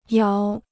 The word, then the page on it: 窑: yáo